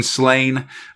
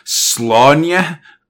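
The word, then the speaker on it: Sláine: The first thing is, like, you'll notice there's a dealy over the A in Slain. Technically, the way you pronounce it is, like, Slaunya, but I'm not going to do that.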